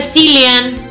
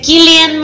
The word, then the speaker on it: Cillian: no se dice Cillian, se dice Killian Murphy.